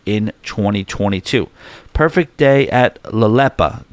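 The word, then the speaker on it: Lelepa: Cruisers, get ready for the Perfect Day at La Pela. Wait, hold on. I think I know how to say this. La Lepa.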